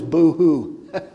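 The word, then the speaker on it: bohu: Void is boo-hoo. This is easy for you to remember, right? Well, that's my understanding, how as closely I can get it from the Hebrew as it's pronounced.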